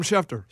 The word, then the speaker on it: Schefter: Adam Schefter.